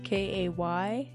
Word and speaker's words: Kayleigh: Yeah, so my first name is spelled Kayleigh, and then Berkana is Berkana.